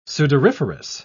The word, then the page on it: sudoriferous: British English